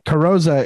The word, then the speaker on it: Carrozza: Like Carrozza isn't the proper way to say it.